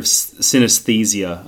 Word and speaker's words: synesthesia: and it's that idea of synesthesia.